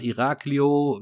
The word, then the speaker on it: Iraklio: Also, wie, ich habe es gerade schon gesagt, Iraklio wird es in Griechen ausgesprochen, ist die größte Stadt auf Kreta, und die fünftgrößte Stadt in Griechenland.